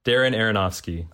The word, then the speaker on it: Darren Aronofsky: The first two syllables of his first and last name rhyme. Rhyme with Aaron. Darren Aronofsky.